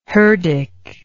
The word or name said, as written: herdic